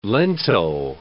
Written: lento

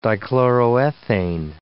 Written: dichloroethane

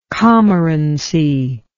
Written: commorancy

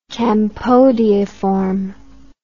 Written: campodeiform

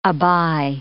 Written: aby

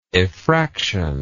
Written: effractor

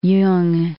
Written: Jung

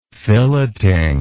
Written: filleting

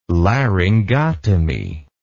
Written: laryngotomy

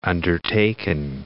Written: undertaken

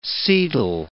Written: setal

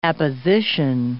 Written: apposition

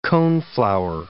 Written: coneflower

